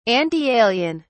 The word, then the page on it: antialien: British English